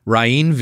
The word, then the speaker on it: Rainn: Rainn Wilson.